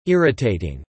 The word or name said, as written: irritating